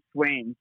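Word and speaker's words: Swain's: And it was called Swanes, but it was spelled like S-W-A-I-N, apostrophe S, like a last name of the person who owned the place.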